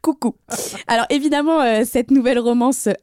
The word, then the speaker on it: Kuku: On les a vus à la Fashion Week, mais aussi à Coucou. C'est le nouveau resto des stars dans le 8e arrondissement. – Ça s'écrit KUKU. – Oui, Coucou ils ont euh, dîné à côté de Leonardo DiCaprio.